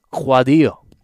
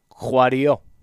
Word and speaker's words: Kouadio: Marc, M-A-R-C, and the last name is spelled K-O-U-A-D-I-O. Guadio. Guadio.